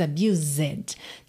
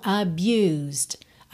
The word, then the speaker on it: abused: Esse E de abused não é pronunciado. Você não diz abused, tá? Então, abused, abused, st, st, é o fechamento dessa palavra.